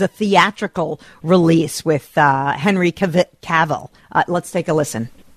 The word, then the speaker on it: Cavill: It is Cavill. It is Henry Cavill. This is the easy way to remember it. Cavill rhymes with travel.